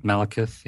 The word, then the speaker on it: Malekith: So we have Malekith. Is that how you say his name? – Malekith, yeah.